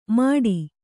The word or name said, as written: ಮಡಿ